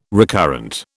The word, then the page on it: recurrent: British English